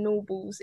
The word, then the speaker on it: Gnorbles: and so it's about teaching them the G in Norbles is silent.